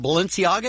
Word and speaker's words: Balenciaga: This shirt from Balenciaga, is that how you say it, Dave? – Yes.